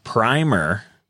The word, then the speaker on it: primer: As you pronounce primer, it means a first coat of paint or to prime a surface for finishing. Then it is pronounced with the long vowel sound, as in prime time, crime, bright, etc.